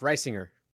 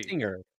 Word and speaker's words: Risinger: It's soft. Risinger. – That's a hard G. – Risinger. It's not hard. There's no hard G in there.